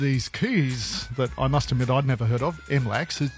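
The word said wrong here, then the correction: MLAK: Did I say that correctly? – Well, it's the Master Locksmith Access Key, MLAC or MILAC for short.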